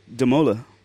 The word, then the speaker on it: De Molen: And my friends that live in the Netherlands say De Molen. – They don't say the N. You don't pronounce the E-N.